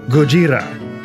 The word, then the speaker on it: Gojira: And on today's show, I'll be insisting on the pronunciation Gojira Consider yourself warned, Erica and Mark.